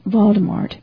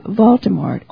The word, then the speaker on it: Voldemort: Now, this name, Voldemort, I may not be pronouncing it as Harry Potter's author wants us to, and I always want to put an R in the middle of the name, and I stopped right here and looked in the book again to be sure I was spelling it correctly. It's V-O-L-D-E-M-O-R-T, and I guess it's pronounced Voldemort.